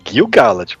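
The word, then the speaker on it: Gil-galad: cada nome. É Gil Galad.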